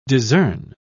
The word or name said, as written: discern